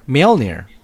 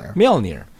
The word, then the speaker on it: Mjolnir: How do you pronounce it? Of all this time you've been watching and reading Thor magazines. How do you say it? – Mjolnir. – Yeah. – Mjolnir. – That's how Mjolnir.